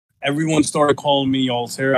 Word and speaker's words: Altair: Altair? Is that how you say it? Or is it Altair? – So, it's Altair, basically from that video game Assassin's Creed 1.